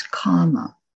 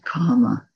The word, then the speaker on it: kama: And the word, as we said, for this whole sense domain that we humans find so enticing is kama, with a long A, kama.